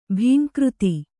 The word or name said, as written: ಭೀಂಕೃತಿ